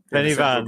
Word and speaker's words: Pen y Fan: Is that how it's pronounced? – Yeah, Pen- yeah. – Pennyvan. Brecon Beacons.